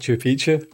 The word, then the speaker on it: Machu Picchu: I'm interested in hearing about Machu Picchu. That's the right pronunciation.